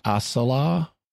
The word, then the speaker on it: as-Salah: And also his name is Commander Hansen al-Salah. – Yeah. – And which, if I'm thinking about it, shouldn't that be pronounced as-Salah? S is one of the sun letters in Arabic, if I'm not mistaken.